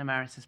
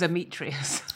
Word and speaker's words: emeritus: And I had to look up how to say it phonetically so that I could say it to the lady without embarrassing myself. – Fucking hell. – Because I thought it was Ametrius.